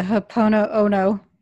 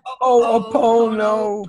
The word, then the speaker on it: Ho'oponopono: And is this something that we can, I'm going to try to say the H word, the Ho'oponopono. I didn't say it right. – Yeah, almost. Ho'oponopono.